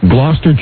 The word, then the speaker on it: Gloucester: From Gloucester, is that how you pronounce it? Yep, Gloucester. That's what I hear.